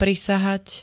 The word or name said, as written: prisahať